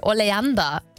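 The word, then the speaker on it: Oleander: Almancası nedir demiştim, tam telaffuzunu düzeltin lütfen demiştim. Gonca yazmış, diyor ki oleanda diye okunuyor dedi.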